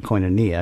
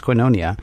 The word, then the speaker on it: Koinonia: We were Southerners, so we called it Koinonia because we didn't know any better. But it's actually pronounced Koinonia. – Hmm. – I looked it up on Wikipedia, so you know it's true. Mm-hmm. Translated from the Greek word, something in Greek, which means communion, joint participation.